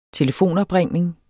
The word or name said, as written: telefonopringning